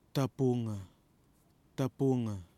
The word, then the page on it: TAPUNGA: kwamera